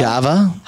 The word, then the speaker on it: Java: Now, is that Hava? – Java. – Hava or Java? – Java. Java.